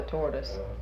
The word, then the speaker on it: tortoise: I think actually the Mock Turtle was talking about the tortoise. They called him Tortoise because he taught us, I think that was. And of course, you don't pronounce the R in British English, so.